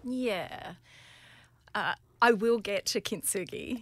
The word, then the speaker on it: kintsugi: And a concept that you work out with your coaching is this idea of kintsugi.